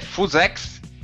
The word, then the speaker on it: Fusex: Exatamente, a pronúncia aqui no Brasil eu não sei como é que é, mas no Por Falar em Corrida é Fusex e nós vamos falar do ASICS Fusex.